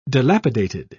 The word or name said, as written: dilapidated